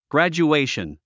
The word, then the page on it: graduation: ɡræ`dʒuéiʃən; グラジュエイション